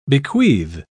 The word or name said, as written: bequeath